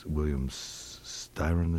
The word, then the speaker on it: William Styron: I think William Styron, is that how you pronounce his name? – Yeah.